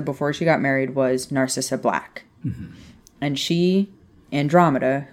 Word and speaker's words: Narcissa: Who is Narcissa? Did I say that right? – Yeah, so, Narcissa is Draco's mom, who is also Sirius Black's first cousin.